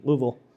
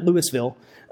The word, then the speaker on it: Louisville: I remember moving my sister-in-law to Louisville, Kentucky, and I said that correctly, Louisville. That's how you say it, not Louisville.